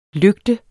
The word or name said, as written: lygte